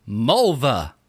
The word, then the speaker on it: Mulva: Here's another great unsexy name from the chat is Mulva. M-U-L-V-A. – Oh, Jesus. Wait, it's Neil from Seinfeld. – It rhymes with vulva.